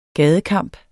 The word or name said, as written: GADEKAMPEN